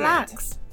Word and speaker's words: BLXST: Blacks, yeah. No, that's how you pronounce it. You said it.